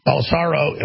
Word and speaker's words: Bolsonaro: I'm not pronouncing his name wrong. Bolsonaro, I mean, he's there.